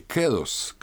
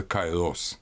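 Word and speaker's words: кэрос (καιρός): Надлежащее время, священное время ритуала – это то, что греки называли словом по-гречески, на самом деле, «кэрос», «кэрос», но у нас обычно произносит на Эрасмов манер «кайрос», надлежащее, подходящее.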